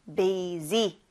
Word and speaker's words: busy: 여기에서 sy여서 약간 c, bc 하고 싶을 수도 있는데 s 발음이 아니라 좀 z 발음에 가까워요. z. 그래서 busy 하면 됩니다.